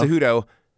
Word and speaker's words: Cejudo: Cejudo is without a doubt an amazing fighter.